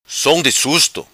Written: Som Di Susto

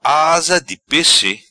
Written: Asa Di Pesse

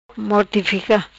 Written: Mortifica

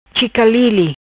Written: Chicalili

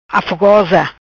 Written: Afogosa

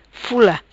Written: Fula

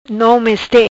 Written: Meste nom

